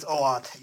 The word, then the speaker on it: OAuth: Yeah, it's OAuth.